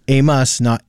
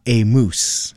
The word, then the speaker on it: Amos: Amos, not a moose.